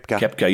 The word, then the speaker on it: Koepka: Koepka, yeah.